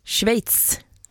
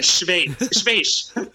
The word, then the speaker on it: Schweiz: Schweiz. – Ja, Gustav Fridolin hade ju lite problem med det för några år sedan, men det stämmer. Schweiz.